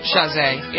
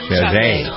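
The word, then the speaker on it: Chasez: Is that how you pronounce his name? – Chazé. Yep. – Chazé.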